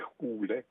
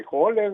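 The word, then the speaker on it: Hole: το ίδιο τον άκουσα και τον ίδιο τον άκουσα σε μια συνέντευξη, όπου έλεγε, ενώ μιλούσε αγγλικά, λέει, ας πούμε, και ο πρωταγωνιστής μου, ο Χάρι Χούλε, το είπε και με νορβηγική προφορά.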